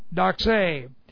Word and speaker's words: doxa: The Greek word is doxē, and this is doxē, D-O-X-A, doxē. It comes from the root, the verb, dokeo, D-O-K-E, D-O-K-E, long O, dokeo.